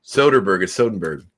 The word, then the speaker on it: Soderbergh: Soderbergh.